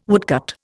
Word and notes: woodcut: Indian

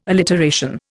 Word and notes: alliteration: Indian

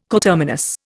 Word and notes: coterminous: Indian

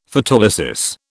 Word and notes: Photolysis: British English

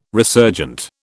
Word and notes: resurgent: British English

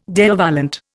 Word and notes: Deo volente: Indian

